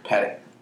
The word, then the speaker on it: Patek: Patek.